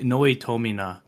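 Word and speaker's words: noitaminA: I always say Noitomina. Japanese, they have characters, you pronounce each of them the same way. So, Noitomina.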